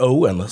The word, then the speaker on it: Eoghan: Han stavar alltså E-O-G-H-A-N. Eller Owen, som Jerry säger.